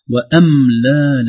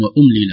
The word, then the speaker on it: وأملى: كلمتان ربما لهما نظير في القرآن، لكن يخشى يعني في الرسم يخشى أن ينطقا بطريقة مختلفة، وأملى لهم، الشيطان سول لهم وأملى لهم، في القرآن أكثر من كلمة أملي لهم إن كيدي متين، فيخشى أن يقول سول لهم وأملي لهم وأملى، سول وأملى لهم بفعل الأمر وليس بفعل الماضي وليس فعل الأمر.